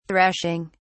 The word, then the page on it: threshing: British English